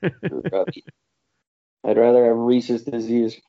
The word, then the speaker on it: Rhesus: No, Rhesus disease. R-H-E-S-U-S.